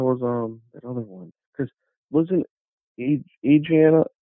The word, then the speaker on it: Adriana: Adrian? Am I saying that right? From Jason X? Adriana? Was her name Adrian or Adriana? From Jason X. – Adriana. – Yeah, right? Adriana.